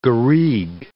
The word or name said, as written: garigue